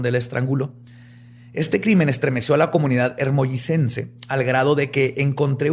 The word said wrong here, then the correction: hermosillense: Espérate, ¿es hermollicense? – Se dice así. – Hermosillense, perdón.